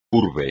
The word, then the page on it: urbe: espagnol